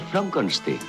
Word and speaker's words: Frankenstein: Se pronuncia Frankenstein.